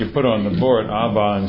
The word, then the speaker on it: Abba: And I have to tell you, it was a little girl in Israel running down the street saying, Abba, Abba. She emphasized the second syllable.